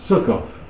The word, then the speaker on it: Sukkot: That is why the place is called Sukkoth. And Sukkoth means more Hebrew words for you. It's pronounced Sukkot in Hebrew.